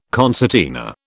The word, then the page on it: concertina: British English